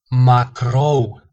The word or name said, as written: macrou